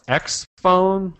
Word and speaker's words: XFON: Another topic, just briefly, the Motorola X Phone, which is spelled X-F-O-N. – X-Phone.